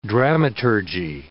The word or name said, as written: dramaturgy